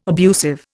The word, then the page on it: abusive: Indian